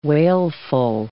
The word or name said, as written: wailful